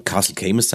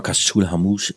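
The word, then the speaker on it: Caisteal Chamuis: Skye, dieser Ort Torraweg, diese Burg, die heißt übrigens, wenn man sie im Gälischen ausspricht, Castle Hamush. Und Castle Camus sagen manche, also Castle Rock, wird auch gesagt im Englischen. Aber Kaschul Hamush ist tatsächlich die korrekte Aussprache.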